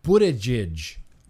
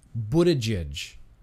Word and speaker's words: Buttigieg: It's Buttigieg. Buttigieg. Like book, B-U, like book, Buttigieg. Buttigieg. I believe that's the way it's pronounced.